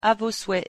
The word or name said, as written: à vos souhaits